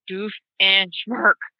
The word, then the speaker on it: Doofenshmirtz: And like he like enunciates every syllable. He's like doof and schmirk.